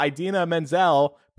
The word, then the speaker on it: Idina Menzel: And he said, and now Idina Menzel, pronounced exactly how it's spelled, reference to the Adele Dazeem flub we spoke about a few weeks ago.